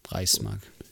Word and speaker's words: Reichsmark: Reichs, R-E-I-C-H-S-M-A-R-K. – Reichs? – Reichsmark. – Reichs? Like Reich? – Reich, yeah.